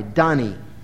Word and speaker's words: Dani: It's spelled D-A-N-I, Donny.